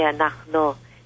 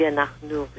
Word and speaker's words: anachnu: I don't pronounce the word anachnu correctly, I say anachno, and it should be anachnu, which means we.